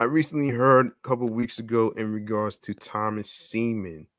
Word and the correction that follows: Steeman: No, Steeman. That rhymes with Seaman, which I didn't even know about this right there.